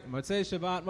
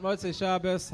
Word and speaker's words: Motzei Shabbos: Motzei Shabbat, Motzei Shabbos, however you want to pronounce it.